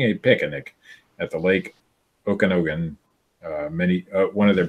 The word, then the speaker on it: Okanagan: It's Okanagan. It's Okanagan Lake.